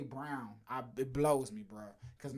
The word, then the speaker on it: Braun: Christian Braun, Brown. Timeout. – I don't know how to say that. I hate that boy's name. – It's spelled Braun, damn it. And he pronounces it Brown.